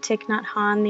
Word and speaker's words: Thich Nhat Hanh: But I feel like you know, I was listening to a podcast with Thich Nhat Hanh the other day.